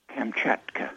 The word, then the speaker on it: Kamchatka: Kamchatka.